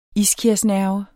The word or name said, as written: iskiasnerve